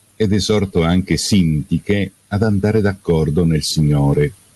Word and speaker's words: Sintiche: Sempre alla linea 12 c'è Sintiche, mentre alla linea 27 troviamo l'accento sulla prima E, Sintiche. Allora devo ricordarvi un vecchio proverbio,